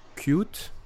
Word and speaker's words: Qt: Euh, on a appris récemment que Cute. alors ça, s'écrit Qt. Mais je crois que ça se prononce Qt, si j'ai bien compris.